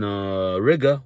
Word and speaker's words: Riga: When I was in Riga, which rhymes with nigga, I was at a nightclub, as you guys know.